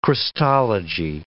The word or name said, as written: Christology